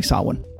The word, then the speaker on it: Samhain: So I do want to say that Samhain, the name of the festival, the ancient festival, it's spelled Samhain. So if you're looking for this on the shelf, know that it looks like Samhain, but it's actually Samhain.